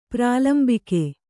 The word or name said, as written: ಪ್ರಾಲಂಬಕ